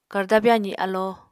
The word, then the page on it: karda-byazi alo: Apatani